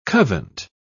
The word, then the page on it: covent: British English